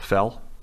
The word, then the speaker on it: Fehl: Andy, how would you pronounce F-E-H-L? Fell? Andrew Fell? – Sure.